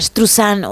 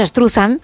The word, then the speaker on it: Struzan: Bueno, pues Drew Struzan o Struzain o como cada uno lo quiera pronunciar, nosotros decimos Struzan Estruzan, que es más fácil.